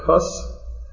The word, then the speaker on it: Hus: Now, to us it looks like Huss, H-U-S-S, but it's pronounced Hus.